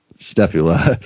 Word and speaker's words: Stefula: And at the 1992 MUFON convention, Stefula, I doubt I'm pronouncing his name right, but Stefula. – Like a scapula. – Yeah, S-T-E-F-U-L-A, Stefula, sure, attended the convention and noted that some of the statements directly contradicted what Linda had earlier told Stefula and Butler.